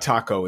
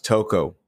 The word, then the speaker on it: toco: Yeah, it's not taco. It's toco.